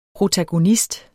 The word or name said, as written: Protagonister